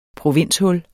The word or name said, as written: PROVINSHULLERNES